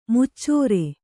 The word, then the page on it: ಮಚ್ಚರಿ: macari; Kannada